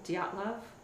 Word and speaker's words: Dyatlov: I think everyone pronounces it that way, but I think the correct way is Dyatlov. – I think you're right, because the T is before the L.